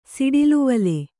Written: ಸಿಡಿಲುವಲೆ

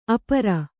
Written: ಆಪೂರ

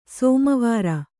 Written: ಸೋಮವಾರ

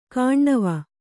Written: ಕಾಣ್ಣವ

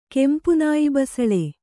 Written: ಕೆಂಪುನಾಯಿಬಸಳೆ

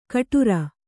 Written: ಗಡುರ

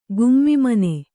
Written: ಗುಮ್ಮಿಮನೆ

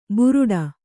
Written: ಬುರುಡ

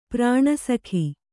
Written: ಪ್ರಾಣಸಖ

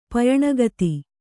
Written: ಪಯಣಗತಿ

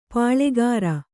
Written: ಪಾಳ್ಳೆಗಾರ

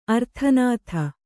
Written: ಆರ್ತನಾದ

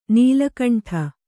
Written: ನೀಲಕಂಠ

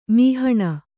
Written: ಮೋಹನಿ

